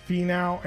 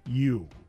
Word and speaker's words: Finau: Well, it's hard because it's when you say it, it's Finau. – Uh huh. – But it's spelled Finau. So Finau.